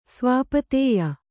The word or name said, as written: ಸ್ವಾಪತೇಯ